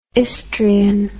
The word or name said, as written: istrian